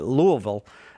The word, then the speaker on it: Louisville: That's how I'm told you're supposed to say it, Louisville. Did I say it right, Dan? – I think you got it right.